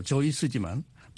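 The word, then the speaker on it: Seuss: 본래 독일어 발음으로 조이스지만 많은 사람들이 수스라고 부르자 그대로 굳어진 것입니다.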